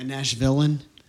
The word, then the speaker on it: Nashvillean: Nashvillean? Is that how you say it? – Yes. I'm one of the few. Yeah. – A Nashvillean? – The big nasty.